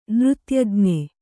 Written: ನೃತ್ಯಜ್ಞ

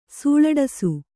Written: ಸೂಳಡಸು

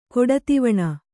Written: ಕೊಡತಿವಣ

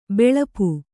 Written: ಬಲ್ಪು

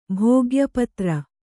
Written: ಭೋಗ್ಯಪತ್ರ

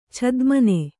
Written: ಛದ್ಮನೆ

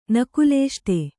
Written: ನಕುಲೇಷ್ಟೆ

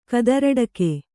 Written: ಕದರಡಕೆ

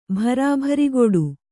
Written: ಭರಾಭರಿಗೊಡು

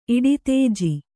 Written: ಇಡಿತೇಜಿ

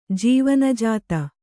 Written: ಜೀವನಜಾತ

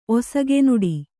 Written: ಒಸಗೆನುಡಿ

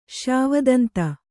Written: ಶ್ಯಾವದಂತ

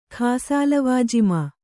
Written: ಖಾಸಾಲವಾಜಿಮಾ

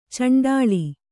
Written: ಚುಂಡಿಲಿ

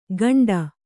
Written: ಗಂಡ

